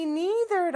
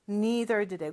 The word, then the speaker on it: neither: 라고 할 때는 me neither 라고 한다고 했는데요. neither는 뭐뭐도 아니다 라는 의미예요. 나도 아니야. 나도 싫어. 라고 할 때는 neither 요 단어를 쓰죠. 그런데 발음은 Neither도 되고, neither도 된다고 했어요.